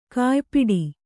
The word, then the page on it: ಕಯಿಪಾಡಿ: kayipāḍi